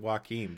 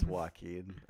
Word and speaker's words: Joaquin: I pronounce it Joaquin,